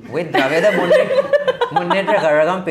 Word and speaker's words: Kazhagam: Just one little piece of news, the Dravida Munnetra Kazhagam, because I'm half Tamil, I can say Kazhagam. – Is he pronouncing it right? – Yes, he is.